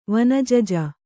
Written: ವನಜಜ

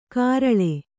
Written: ಕರಳೆ

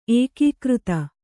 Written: ಏಕೀಕೃತ

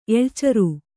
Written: ಎಳ್ಚರು

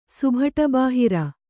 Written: ಸುಭಟಬಾಹಿರ

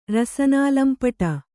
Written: ರಸನಾಲಂಪಟ

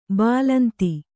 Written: ಬಾಲೇಂದು

